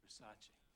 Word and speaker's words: Versace: It's a Versace. – It's Versace. – What? – Versace. – It's pronounced Versace.